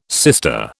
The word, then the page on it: sister: British English